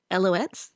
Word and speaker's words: Alouettes: Alouettes. – Thank you, Alouettes.